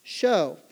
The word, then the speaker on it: shew: Now, it's spelled S-H-E-W, pronounced show.